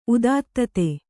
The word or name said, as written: ಉದಾತ್ತತೆ